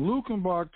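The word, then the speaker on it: Luckenbach: And Lukenbach, Texas, now you can look this up online. I'm on Wikipedia right now. You can go there too. Look up Lukenbach, Texas. That's spelled Luke, L-U-C-K, like luck.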